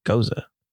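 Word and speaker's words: Gose: Two roads is driving a tanker truck down the road less traveled with this Rosé Gozé, pronounced Goza. – Never mind. Let me redo. I've been calling it Rosé Gozé this whole time. – You have. – Screw it. I just really like calling it Rosé Gozé, but it's a Goza, not a Gozé.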